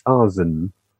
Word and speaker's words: Arzen: Arzen, is that you say? – Arzen, or probably a soft S, Arzen.